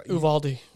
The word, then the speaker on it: Uvalde: Uvalde.